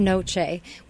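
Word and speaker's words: noce: And it's pronounced Noche, which also sounds like the Spanish word for night.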